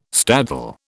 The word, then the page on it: staddle: British English